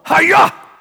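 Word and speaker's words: haya: The Hebrew word, it's H-A-Y-A. Who wants to pronounce that for me? All right, anybody here do karate? David, karate. I mean, come on. Hi-ya! Right? That's how I would pronounce it.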